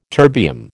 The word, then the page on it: terbium: American English